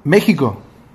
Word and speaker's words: México: Profe, ¿sabe cómo se escribe usted México? ¿Sabe cómo se escribe México? – Sí. – No se escribe con J. Sabía esto, ¿no? Se pronuncia como una J.